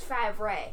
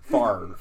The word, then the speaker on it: Favre: Actually, it's pronounced Favre. – Favre. – Okay. – The R actually is pronounced before the V, even though it's the opposite spelling.